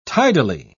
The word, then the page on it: tidily: British English